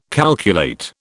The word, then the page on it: calculate: British English